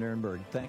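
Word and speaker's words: Nirenberg: Mayor Ron Nuremberg, am I saying it right? – Nuremberg, that's correct.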